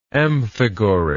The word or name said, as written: amphigory